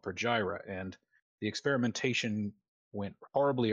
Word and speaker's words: Pergyra: became a city called Pergyra. Um, How do you spell that? Uh, P E R G Y R A, Pergyra.